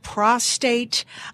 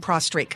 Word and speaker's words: prostate: And on our last show, show, we seem to have a lot of questions and concerns about prostate. Did I say that right? – Yeah, I always get prostate and prostrate confused sometimes.